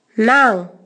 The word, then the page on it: Nangai: Naka